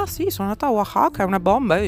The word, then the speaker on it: Oaxaca: Eh, ed eccoci qua, quindi sono arrivata ieri a Oaxaca, che però si scrive Oaxaca, che però si legge Oaxaca